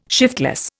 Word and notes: shiftless: Indian

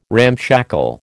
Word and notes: ramshackle: American English

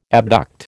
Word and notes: abduct: American English

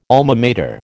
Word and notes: Alma Mater: American English

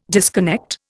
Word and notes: disconnect: Indian

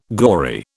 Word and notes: gory: British English

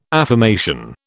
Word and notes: affirmation: British English

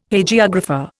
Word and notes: Hagiographer: Indian

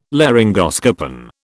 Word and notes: laryngoscopen: British English

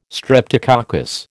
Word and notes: streptococcus: American English

